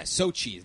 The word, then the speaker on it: Sochi: So yeah, Sochi. That's how you say it. Sochi.